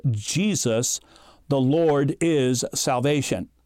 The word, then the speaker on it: Jesus: And so in English, we pronounce Jesus' name as Jesus.